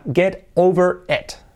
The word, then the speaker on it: get over it: Supéralo sería get over it. Get over it. Get over, fijaros, get o, la T se convierte en una R suave de nuestras letras semiclave de pronunciación.